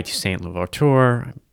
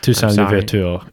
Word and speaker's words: Toussaint Louverture: And then there was a slave rebellion led by Toussaint Louverture. Butchering the name. – Toussaint Louverture, yeah.